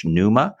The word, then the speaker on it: Neuma: He is the global senior pastor of Neuma Church, Neuma, that's spelled N-E-U-M-A, after, of course, the Greek word pneuma, meaning spirit, Holy Spirit.